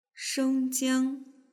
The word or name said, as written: shengjiang